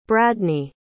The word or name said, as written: bradney